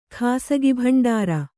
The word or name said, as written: ಖಾಸಗಿಭಂಡಾರ